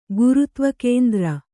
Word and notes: ಗುರುತ್ವಕೇಂದ್ರ: gurutva kēndra

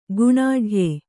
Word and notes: ಗುಣಾಢ್ಯೆ: guṇāḍhye

